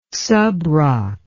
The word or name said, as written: subroc